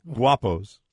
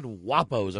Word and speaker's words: Guapos: Guapos. – Guapos. – Have you been by a Guapos? – Spell it. – Oh, I thought you said Wapos. – No. – Was it an Italian Guapos. G-U-A-P-O-S.